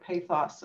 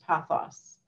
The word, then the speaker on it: pathos: I would say, okay, so I'm going to make one last note on the word pathos. So I was talking to someone yesterday and they were like, it's pronounced pathos. Pathos. So I'm going to try to change my pronunciation of that word now.